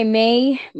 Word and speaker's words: May: So I just keep it short and I'm just like, my name is May. – May. – Like the month.